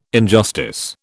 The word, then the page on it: injustice: British English